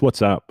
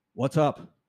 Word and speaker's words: WhatsApp: It's WhatsApp, by the way. WhatsApp. – What's up? – WhatsApp. – App? – App. It's an app. – The A is silent? – No. Ah. What's app? – Up. What's up? I'll never get it right.